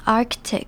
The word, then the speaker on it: Arctic: 这个字要注意的地方，第一个是它的重音在第一个音节，就是 A-R-C 那边念成 Arctic。那这边要注意的是 ARC，它的 C 是要发音发成一个可的声音， Arctic，注意 ARC 的 C 是要发音，不能直接念 Arctic，而是要念成 Arctic。